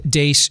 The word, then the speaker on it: Deace: You can like us on Facebook and follow us on Twitter at Steve Dace Show. Last name is spelled D-E-A-C-E.